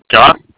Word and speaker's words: Jah: It's like J-A-H, if you want to do like the pronunciation part of it.